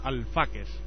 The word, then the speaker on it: Alfaques: Es el caso del camping Los Alfaques. ¿Lo he dicho bien? Perfecto. – Correcto.